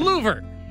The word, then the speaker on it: Louvre: The Louvre? Did I say that right? – The Louvre, yes, you did. – You said it better than I just said it. The L Museum, whatever it's called. The L-O-U-V-R-E Museum. – The Louvre. – That's right, the Louvre. – The Louvre. – Rhymes with a vacuum company.